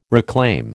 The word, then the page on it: reclaim: American English